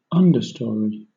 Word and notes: understory: British English

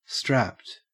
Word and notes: strapped: Australian English

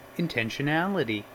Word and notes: intentionality: American English

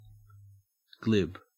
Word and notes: glib: Australian English

